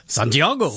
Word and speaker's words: Santiago: Our partnership's performance manager, Seb, or Sebastian, is spending some time in South America, and he met an American traveller in Santiago. Have I said that correctly? – Why not? I'm sure it's Santiago.